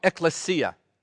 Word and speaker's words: ekklesia: That Greek word is called ekklesia. All right, that's how you say it.